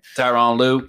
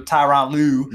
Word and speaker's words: Tyronn Lue: Tyrone Lue. I can never say his name. – Tyronn Lue. Tyronn Lue mm-hmm.